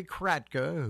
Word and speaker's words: Kratka: We started off with, of course, nothing but old 45s with Larry Kratka, my buddy Larry Kratka, who rhymes with Palatka.